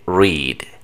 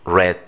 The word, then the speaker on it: read: En presente se dice read, en pasado read y en pasado participio red también, como el color rojo, así es como se pronuncia.